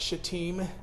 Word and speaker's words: Shittim: It's pronounced Shittim, but it's spelled fun.